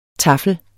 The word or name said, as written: taffel